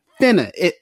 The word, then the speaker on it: finna: Look, finna, F-I-N-N-A. Okay. – That's how you now, say it. – That is how you say it.